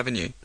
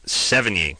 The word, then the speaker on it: Sevigny: Chloe Sevigny. – Sevigny.